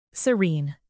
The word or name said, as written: serene